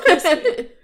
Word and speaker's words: Chrispy: And my name is Chrispy. And that is spelled C-H-R-I-S-P-Y.